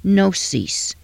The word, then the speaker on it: gnosis: The Greek word for knowledge is gnosis, G-N-O-S-I-S. The G is silent.